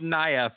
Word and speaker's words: Nia: It's pronounced Nia, first of all.